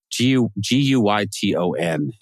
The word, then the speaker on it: Guyton: How, how do you say his last name? Guyton. Uh, Guyton, Guyton. Yeah. – Guyton. Mm-hmm. Okay, I would have said Guyton.